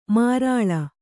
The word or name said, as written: ಮಾರಾಳ